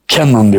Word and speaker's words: Kennan: Amerikan Dışişleri Bakanlığı siyaset planlama müdürü George Kennan. Kennan diye okunuyor. Bunun hazırlamış olduğu bir kararname var.